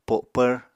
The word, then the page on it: po7pvr: Apatani